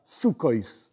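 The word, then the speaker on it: Sukkos: O pronunciado con la pronunciación Ashkenazí, que Ashkenazí significa los judíos que venían de Europa Oriental, Occidental, etc. Diferente de Sefaradí, judíos que venían de España, Siria, Norte de África. Sukkois. Ok.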